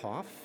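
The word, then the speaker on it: Hauff: I am Bradley Hoff.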